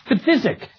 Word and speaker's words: phthisic: And here, it's physic, spelled P-H-T-H-I-S-I-C, which sounds like a mispronunciation of physic, but is in fact the regional word for asthma.